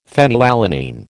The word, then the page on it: phenylalanine: American English